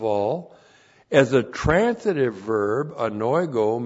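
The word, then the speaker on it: Anoigo (ἀνοίγω): And then we have anoigo. A-N-O-I-G, long O. Anoigo.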